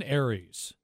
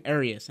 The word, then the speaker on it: Aries: Well, how do you say his last name? – Austin Aries. – Okay, I keep calling him Arius, and I keep getting in trouble by everybody.